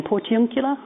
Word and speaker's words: Portiuncula: Works like Kenner's, tell me how to pronounce it again, Portiuncula? Thank you.